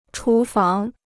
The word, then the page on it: 厨房: chú fáng